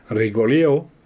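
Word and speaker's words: Rigolleau: Rigolo, hein. En prononciation espagnole c'est Rigolio, dont il deviendra directeur général.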